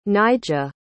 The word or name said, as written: Nyjah